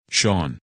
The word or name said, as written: Sean